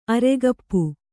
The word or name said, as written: ಅರೆಗಪ್ಪು